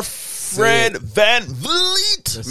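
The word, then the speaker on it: Fred VanVleet: All-star Fred Van Vliet. Make sure you say it right, because they say if you don't say it, they're pure idiot out there. They can't say his name.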